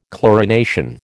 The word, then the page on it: chlorination: American English